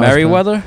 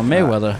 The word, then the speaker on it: Mayweather: Merriweather, no. Mayweather. Mayweather? The weather in May, yeah. Floyd Money Mayweather. – Pronouncing his name all fucking wrong this whole time.